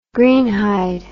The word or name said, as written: greenhide